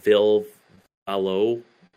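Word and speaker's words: Ville Valo: The new Vil Valo, that's how you say his name.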